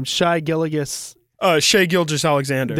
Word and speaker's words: Shai Gilgeous-Alexander: Another pick I like is something about, and I can't pronounce his name, Shai Gilgeous-Alexander.